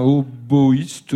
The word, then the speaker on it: hautboïste: Un hautboïste, oui, je pense qu'on le prononce comme ça. Oui. Il y a un tréma sur le oui.